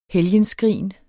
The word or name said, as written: helgenskrin